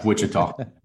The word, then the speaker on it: Wichita: There you go, Rescue 2, Wichita, Kansas. – I'm sorry, is that the correct pronunciation or? – Nah, it's close enough though. Nah, Wichita.